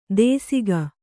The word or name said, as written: ದೇಸಿಗ